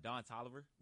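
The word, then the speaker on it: Don Toliver: Don Tolliver.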